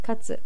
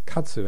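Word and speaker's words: Katsu (活): And the pronunciation, once again, is? – Katsu. – Katsu, and it mm-hmm.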